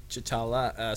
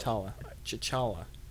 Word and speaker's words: T'Challa: King T'Challa sitting in his throne. Did I say that right? – Yep. – T'Challa.